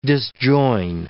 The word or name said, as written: disjoin